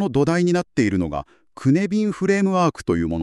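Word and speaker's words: cynefin: はい、シュネフィンと綴って、ウェールズ語で居場所とか複数の帰属先といった意味を持つそうですね。発音はクネビン。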